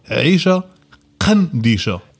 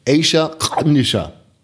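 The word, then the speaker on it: Aisha Qandisha: Du hast es perfekt ausgesprochen, weil ihr Urdeutschen einen Buchstaben nicht drauf habt, den welchen? – Ja. Okay. Weil die heißt eigentlich Aisha Khandisha. – Aisha Khandisha.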